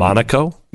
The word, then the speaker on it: Monaco: But Monaco? – It's Monaco. That's how you actually pronounce it. – Is it really? – Yes. – Oh, excuse me, Mr. That's how the residents do it.